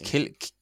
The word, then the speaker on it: Cillian: It's Cillian. – Sorry. – I think it is Cillian. Everyone calls it Cillian Murphy.